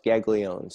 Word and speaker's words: Guaglione: My last name is pronounced Gaglione, so... Just take the U out and you've got it, Gaglione.